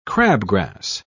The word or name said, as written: crabgrass